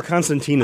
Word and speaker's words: Constantine: Well, Constantine is what they call it on the show.